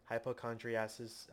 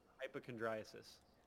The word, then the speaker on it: Hypochondriasis: Because outside of all of them, so It's Coming, The Dance, Hypochondriasis, I think that's how you pronounce it, and Hypochondriasis. – Okay, Hypochondriasis, sorry.